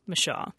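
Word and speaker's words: Micheaux: but more often Mashaw. Since this footage is quite rare and about all we have from people who knew him, I personally take my cues from it and say Mashaw.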